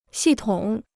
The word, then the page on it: 系统: xì tǒng